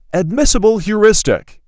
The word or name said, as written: Admissible heuristic